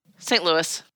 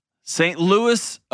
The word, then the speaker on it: St. Louis: How do you say it? – St. Louis. – St. Louis. Oh, it's not Louisville. – No. That's in Kentucky. Louisville.